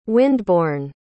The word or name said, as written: wind-borne